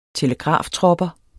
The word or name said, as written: telegraftropper